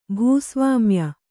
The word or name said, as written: ಭೂಸ್ವಾಮ್ಯ